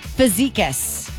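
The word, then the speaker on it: Fazekas: We are looking for Chris Fizikas. – Chris Fizikas. – Fizikas. And I hope that we're pronouncing the last name correctly, but just in case, it's F-A-Z-E-K-A-S.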